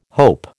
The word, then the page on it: hope: American English